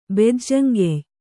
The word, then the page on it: ಬೆಜ್ಜಂಗೆಯ್: Kannada